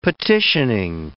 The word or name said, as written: petitioning